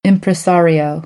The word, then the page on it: impresario: American English; ɑːr